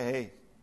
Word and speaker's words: He: It's called Hey. Everyone say Hey. The letter, this is the fifth letter of the alphabet, Hey.